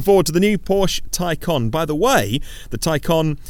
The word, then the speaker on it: Taycan: Tay as in necktie, con as in con artist. It's spelt Taycan, it's pronounced Taycan.